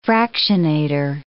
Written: fractionator

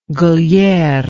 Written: gliere

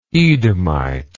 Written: edomitic

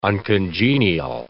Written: uncongenial

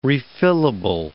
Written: refillable

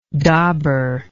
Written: dobber

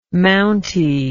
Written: mounty

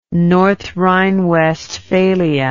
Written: north rhine westphalia

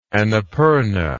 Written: anapurna

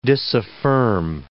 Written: disaffirm

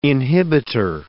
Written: inhibitor

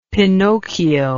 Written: pinocchio

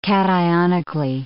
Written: cationically

